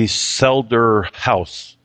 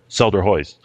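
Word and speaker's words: Selderhuis: Selder House. I think the H-U-I-S is pronounced House in Dutch, or Selder Hoist.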